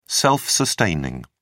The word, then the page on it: self-sustaining: British English